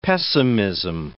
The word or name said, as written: pessimism